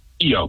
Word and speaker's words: Keo: Keo, K's like a key with an O on the E.